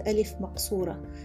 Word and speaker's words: alif maqsura: it's called alif maqsura because as it occurs at the end of the word, it's shorter than a long A or it's uh, unstressed and therefore short. It sounds like a short letter, a short letter A.